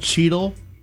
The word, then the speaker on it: cheetle: Like, this town is C-H-E-A-D-L-E, but the word, like, for the stuff on your fingertips is Cheetle, C-H-E-E-T-L-E.